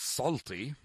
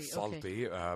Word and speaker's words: Salty: Did I say your last name right? – Well, yes. I mean, it's Salty. – Salty, okay. – Salty, but certainly I like the way you say it, Kamla.